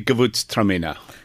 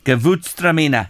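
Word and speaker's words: Gewürztraminer: Yeah, it's the Gewurztraminer. Gewurztraminer.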